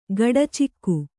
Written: ಗಡಚಿಕ್ಕು

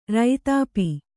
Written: ರೈತಾಪಿ

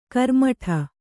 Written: ಕರ್ಮಠ

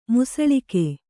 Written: ಮುಸಳಿಕೆ

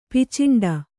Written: ಪಿಚಂಡಿ

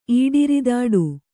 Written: ಈಡಿರಿದಾಡು

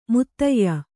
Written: ಮುತ್ತಯ್ಯ

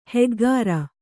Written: ಹೆಗ್ಗಾರ

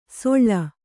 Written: ಸೊಳ್ಳ

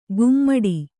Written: ಗುಮ್ಮಡಿ

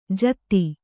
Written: ಜಾದೂ

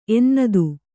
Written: ಎನ್ನದು

